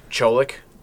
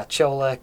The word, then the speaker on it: Ciolek: C-I-O-L-E-K, but it's Ciolek, almost like there's a W in it, yes.